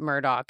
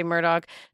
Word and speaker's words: Murdaugh: So I just feel like it's important that we clarify, although it is spelled Murdaugh, they pronounce it Murdaugh. – They do. – So many places. I've checked 1,000 different places. They themselves say Murdaugh.